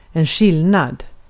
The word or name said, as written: ayrım